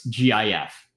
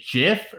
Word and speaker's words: GIF: How do you pronounce GIF? Like the image is? Do you say GIF or do you say GIF? – It's GIF.